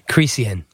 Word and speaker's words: Cricien: That's the appropriate moniker, because I'm glad to say it, because I'm not sure if I could pronounce your last name correctly, and I don't want to be insulting. – How do you pronounce it? – Cricien.